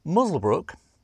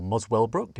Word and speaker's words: Muswellbrook: Muswellbrook, or Muswellbrook, depending on how you want to pronounce it, is a quiet town of about 12,000 people in the upper Hunter region of New South Wales.